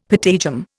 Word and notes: patagium: Indian

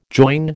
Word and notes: join: American English

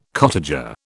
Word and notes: cottager: British English

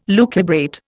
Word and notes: lucubrate: Indian

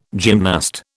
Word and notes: gymnast: British English

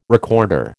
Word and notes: recorder: American English

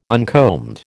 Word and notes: uncombed: American English

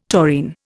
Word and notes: taurine: Indian

